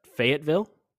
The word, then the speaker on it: Fayetteville: You guys ready to go to Fayetteville? – That is correct.